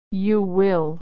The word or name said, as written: you will